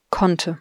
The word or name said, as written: konnte